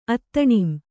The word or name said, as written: ಅತ್ತಣಿಂ